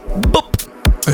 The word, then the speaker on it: Boop: It's very important to pronounce that as Boop. – As short as possible. – Yes, very short.